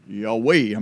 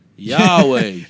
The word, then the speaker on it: Yahweh: So how do you pronounce YHWH? Well, we Americans, we just try and Yahweh, I mean, or whatever. I mean, you start to say it. – Yahweh. – And you just put vowels in it, even right.